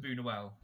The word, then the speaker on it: Buñuel: Buñuel. Is that how you say it? Bunuel, Lewis Bunuel.